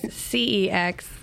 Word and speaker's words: CEX: And I wanted to clarify that is CEX versus DEX. – Oh. – Yeah, it's a soft C.